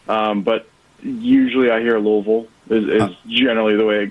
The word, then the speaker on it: Louisville: I think I've got the I had Creighton over Louisville.